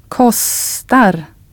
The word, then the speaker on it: kostar: Now try a long consonant sound, a long S, in the word for cost. – Kostar.